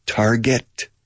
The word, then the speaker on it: target: Por favor, fíjate en la pronunciación. Target. Get. ¿Cómo se dice conseguir? Get. Target. Gedura. No digáis target.